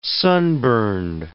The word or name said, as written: sunburned